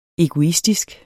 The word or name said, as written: egoistisk